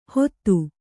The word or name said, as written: ಹೊತ್ತು